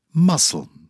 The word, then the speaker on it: muscle: C. Indictment. Muscle. Silent C.